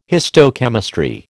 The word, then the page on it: histochemistry: American English